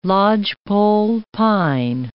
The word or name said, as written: lodgepole pine